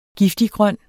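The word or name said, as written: giftiggrøn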